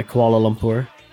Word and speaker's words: Kuala Lumpur: Yeah, Kuala Lumpur.